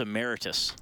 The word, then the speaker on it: emeritus: including last week with John Shannon when I called him a broadcaster emeritus, and he's like, no, it's emeritus.